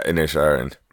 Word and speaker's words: Inisherin: It is Colin Farrell, Brendan Gleeson, Kerry Condon in The Banshees of Inisherin. Have I said that right? – Yeah, Inisherin.